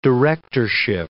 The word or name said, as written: directorship